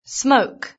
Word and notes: smoke: British English

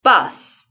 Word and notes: bus: British English

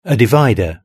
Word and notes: a divider: British English

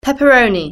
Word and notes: pepperoni: British English

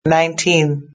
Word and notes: 19: British English

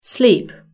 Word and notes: sleep: British English

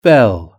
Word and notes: bell: British English

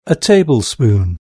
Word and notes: tablespoon: British English

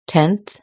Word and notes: tenth: British English

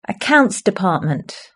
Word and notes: accounts department: British English

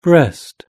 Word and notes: breast: British English

